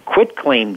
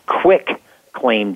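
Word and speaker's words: quitclaim: Uh, a, a quit claim deed, people think it's nice and easy. They mispronounce it as quick claim deed, Q U I C K, it's quit, Q U I T.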